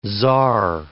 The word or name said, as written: czar